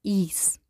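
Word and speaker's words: ís: Und das I mit einem Apostroph oben drüber wird ausgesprochen wie im Deutschen das normale I. Und ein Beispielwort dafür ist zum Beispiel das Wort Ease, was Eis heißt.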